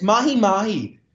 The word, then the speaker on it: mahi mahi: It's mahi mahi. Mahi mahi, mahi mahi. Dolphin fish. – Dolphin fish. Dorado.